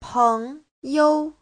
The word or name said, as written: peng2you5